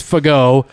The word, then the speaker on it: Fago: His last name is Fago. It's spelled in a very unfortunate way. – Yeah, be careful with that one. – I'm sure he got picked on a lot, but it's pronounced Fago.